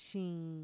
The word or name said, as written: shiin